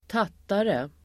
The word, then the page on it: tattare: [²t'at:are]